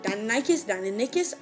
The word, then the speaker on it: Deinonychus: Deinonychus.